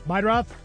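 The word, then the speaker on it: Meidroth: Chase Murdaugh? The White Sox guy? – Meidroff. – How do you say that? – Meidroff. – Meidroff. Meidroff.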